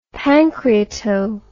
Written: pancreato

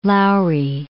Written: Lowry

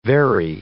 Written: very

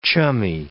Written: chummy

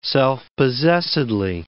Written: self-possessedly

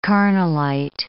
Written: carnallite

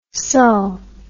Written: sull

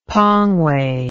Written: Pangwe

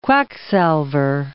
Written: quacksalver

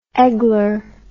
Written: eggler

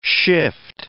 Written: shift

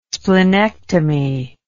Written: splenectomy

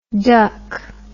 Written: DUKW

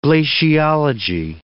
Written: glaciology

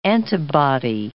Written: antibody

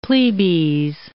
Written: plebes

